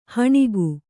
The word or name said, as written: ಹೆಣಿಗೆ